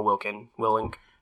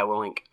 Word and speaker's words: Willink: And you told me to listen to the podcast with Joe Rogan and Jocko Willink, yeah, that's how I say his name. – Yeah, Willink.